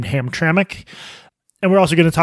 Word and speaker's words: Hamtramck: Hamtramck.